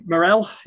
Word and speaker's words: Morel: Morel, is it? – Yeah, you got it, bro.